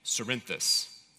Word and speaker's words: Cerinthus: there was a man named Cerinthus, or Carinthus. I'm pretty sure it's with a soft C, but Cerinthus.